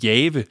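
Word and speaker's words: Jahve: JHVH, som man læser på hebraisk, eller man kunne oversætte det med, eller udtale det Jave.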